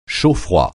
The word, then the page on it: chaud-froid: français